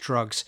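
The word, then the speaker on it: Drukqs: I don't know exactly how you pronounce it, but it's spelt D-R-U-K-Q-S. I think it's pronounced Drugs.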